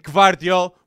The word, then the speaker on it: Gvardiol: It didn't look good for Upamecano, and Gvardiol was the elite, with Schlotterbeck too, center back that everybody was impressed. So, I said the name right. – you did. You did say it right.